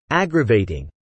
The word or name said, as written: aggravating